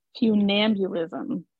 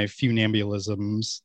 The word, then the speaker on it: funambulism: Funambulism. Funambulism. – Mm-hmm. Right. – Funambulism. Okay. – So we will, I'm thinking we will of like be ambulance. Fun ambulance. Funambulism.